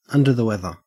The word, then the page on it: under the weather: Australian English